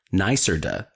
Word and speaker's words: NYSERDA: Sun Common is a NYSERDA, NYSERDA, pronounced NYSERDA, gold quality solar installer, which means they exceed annual quality assurance criteria and consistently provide top tier performance.